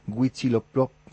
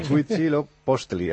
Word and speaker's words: Huitzilopochtli: Nos dice Arturo Black Fonseca, en el foro me llama poderosamente la atención, que la pronunciación correcta en castellano es Huitzilopochtli. Bueno, ya estamos. Huitzilopochtli.